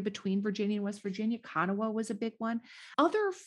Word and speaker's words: Kanawha: Kanawha. – What does that mean? – That is named after a river that is spelled K-A-N-A-W-H-A. It's a Native American word, Kanawha.